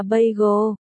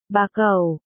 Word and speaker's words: bagel: Phải đọc là bagel, bà cẩu, bagel, chữ cẩu phải uốn lưỡi lên.